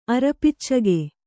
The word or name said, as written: ಅರಪಿಚ್ಚಗೆ